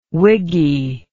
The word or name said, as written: wiggy